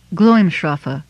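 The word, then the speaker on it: Glǽmscrafu: Gloimshrafu, in which the SC is pronounced as SH, is Anglo-Saxon, Caves of Radiance, with the same meaning as Aglarond.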